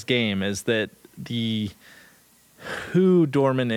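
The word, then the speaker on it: Dormin: Dorman, is that how you pronounce it? – Doorman.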